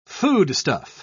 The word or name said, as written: foodstuff